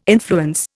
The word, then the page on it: influence: Indian